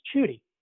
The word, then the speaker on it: Chudy: Apparently, it's Chudy, like rhymes with Judy.